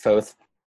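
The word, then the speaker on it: Phauxth: I pronounce it Foth.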